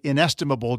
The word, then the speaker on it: Inestimable: Inestimable. Just make sure that you say it with the right number of syllables. I-N and the word estimable.